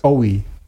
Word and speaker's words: OI: OI. OI, ja. ein sehr internationales Projekt. Wie, wie ähm, David Bowie ohne B, o OI.